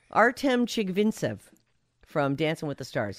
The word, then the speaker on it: Chigvintsev: Artem Chigvincent. Chigvintsev.